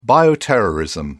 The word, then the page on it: bioterrorism: British English